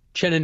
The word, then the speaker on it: Uchenna: Chenna Nwosu. – Nwosu. Okay. – It's spelled like Uchenna, but the U is silent.